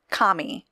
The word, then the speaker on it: Khmer: First, Khmer is pronounced, pronounced Kami, as in like K M A I. And so hopefully I'm still not saying that incorrectly, but Kamai?